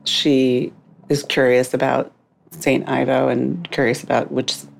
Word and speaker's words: Ivo: St. Ivo, or Evo, depending on how you say it, but I'm going with Ivo, and it became this kind of almost like talismanic thought for the character as she moves through the a couple of days.